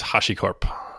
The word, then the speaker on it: HashiCorp: It's HashiCorp.